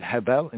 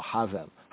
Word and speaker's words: Hevel: Hevel in the Hebrew, Hevel. Some modern Yehudi and Jews would say Hevel, right? Or Havel. They'll pronounce it as Havel.